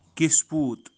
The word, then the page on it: kispoot: kisput